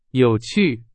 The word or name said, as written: 有趣